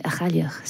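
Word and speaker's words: A' Chailleach: Achailach. So all right. Yeah, der har du så altså også den korrekte udtale af Kim Carneys album, og titlen hentyder til en karakter i irsk og skotsk gælisk mytologi.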